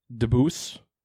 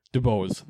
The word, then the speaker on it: DuBose: The next draft pick, Grant DeBose. That's not how you say it. DeBose, that's what it is, because it's DeBose.